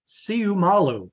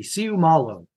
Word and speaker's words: Siumalu: Isaac Siumalu. I mean, every letter in Polynesian names is pronounced. So it's easy. Siumalu.